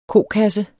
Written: kokasse

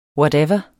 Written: whatever

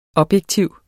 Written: objektiv

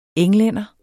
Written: englænder

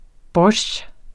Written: borsjtj

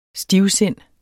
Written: stivsind

